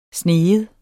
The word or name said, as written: sniger